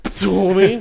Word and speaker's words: Ptolemy: I don't know if you pronounce the T, the P, but it's spelled P-T. So okay. How the fuck do you pronounce that shit? – Ptolemy. You got to make a bit of a spitting noise.